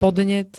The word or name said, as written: podnet